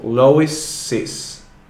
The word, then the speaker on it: Lois's: But the pronunciation is is. So Lois's. – Lois's. – Brother. Mm-hmm. I don't know. Yeah. – Yeah, Lois's brother. So it adds an extra syllable to the end of the word.